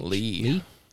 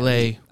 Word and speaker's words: Leigh: How do you pronounce L-E-I-G-H? – Lee. – Or is it? – Yeah. Lee. – I think it's Lee, though.